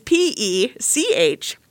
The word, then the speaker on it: Pechacek: It is Pechacek. – C-E-K, no matter how hard.